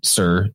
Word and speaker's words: Tyre: It goes back to the Phoenicians and their city, which people pronounce as Tyre, but it's actually Sir, T-Y-R-E is pronounced Sir.